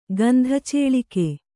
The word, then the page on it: ಗಂಧಚೇಳಿಕೆ: Kannada